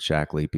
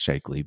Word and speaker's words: Shaklee: So I've you know we we recommend and have taken for decades uh, Shaklee products, and most of the time when you say the word Shaklee, people are well they always pronounce it wrong and call it Shakely, but they always go, mm-hmm.